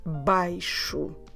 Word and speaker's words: baixo: I repeat, baixo.